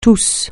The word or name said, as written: tous